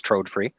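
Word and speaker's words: Trådfri: It's spelled like Tradfree, but I was corrected by a Swede, so it's Trodefree.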